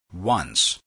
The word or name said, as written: once